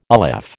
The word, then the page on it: aleph: American English